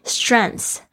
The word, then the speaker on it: strengths: Strengths, S-T-R-E-N-G-T-H-S。那 strengths 到底要怎么样子去发音它呢？特别是后面有 th 又有一个 s 的发音。